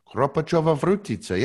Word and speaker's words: Kropačová Vrutice: It's Kropačová Vrutice. – Kropačová Vrutice. Yeah, that was good pronunciation.